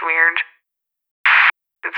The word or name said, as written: Weird